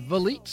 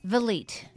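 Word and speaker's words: Vliet: V-I-L-E-T. – Valete. – Valete. – I would have said Valet, you know, like a filet. – Or Valet. No, it's Valete Street.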